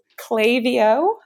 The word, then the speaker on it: Klaviyo: it is Klaviyo. – Okay. – Like clay, the letter V, letter O.